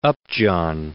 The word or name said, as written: Upjohn